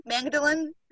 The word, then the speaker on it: Magdalene: Mary Magdalene? – Magdalene. – is that a silent G? – No, you gotta hit the G, but you weren't Magdalene, there you go.